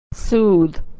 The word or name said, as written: soothe